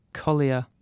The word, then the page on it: collier: British English